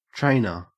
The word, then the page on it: trainer: Australian English